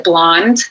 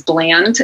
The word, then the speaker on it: blaand: And it's called, I pronounce it blonde, because the other way that you would pronounce it is bland, and I don't like that. but it's spelled B-L-A-A-N-D.